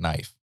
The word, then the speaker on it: knife: There's no reason to have a K in knife. That's stupid. – It is stupid. The K is silent.